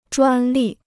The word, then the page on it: 专利: zhuān lì